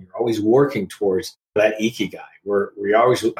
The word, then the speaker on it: ikigai: Dive deep into your ikigai.